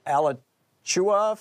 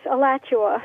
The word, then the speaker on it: Alachua: Tom Hartman with you and Terry in Alachua, Florida. Am I saying it right, Terry? – Close. Alachua.